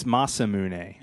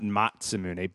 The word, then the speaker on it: Masamune: It's Masamune. – Yeah. – And not Matsumune.